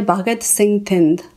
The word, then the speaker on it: Bhagat Singh Thind: Am I saying that correctly? I want to make sure that I said it properly. – I think it's probably Bhagat Singh Thind. – Bhagat Singh Thind. But that's okay.